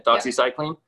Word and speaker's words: doxycycline: Doxycycline.